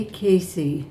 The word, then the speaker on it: Casey: For sound quality purposes, could you please say your name and spell your last name? – Betty Casey, C-A-S-E-Y.